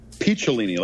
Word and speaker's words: Picciolini: Now, let me ask you: is it Picciolini or Piccolini? – Picciolini, like a peach.